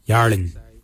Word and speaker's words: YARRLINY: Narin is spelled N-G-A-R-I-N-Y. Narin is your ribs. For your skin, we say yarlin. Yarlin is your skin. Yarlin is spelled Y-A-R-R-L-I-N-Y.